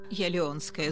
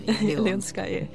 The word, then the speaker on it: Leonskaya: Jetzt aber zu unserem Studiogespräch, in dem ich anfangs mit ihr klären wollte, wie genau ihr Nachname ausgesprochen wird. Geschrieben wird er eigentlich Leonskaya. Aber die Aussprache, die ist natürlich anders. – Jelionskaya, so wie Leone. – Jelionskaya.